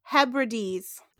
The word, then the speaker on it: Hebrides: All right, it's pronounced Hebrides.